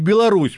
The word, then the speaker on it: Беларусь: Медведев говорит, вот там в Беларуси, он говорит, извините, Дмитрий Анатольевич, Правильно говорит Беларусь, вторая А, третья ну, в Насевадово.